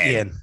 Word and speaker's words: Iain: I apologize for not saying his name right. It's Ian McIntyre. I know his name is spelled like I-A-I-N. – Yeah, it's Ian.